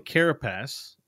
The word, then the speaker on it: carapace: you put emphasis in a weird way that made it seem dirty. – Carapace.